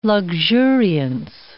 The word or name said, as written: luxuriance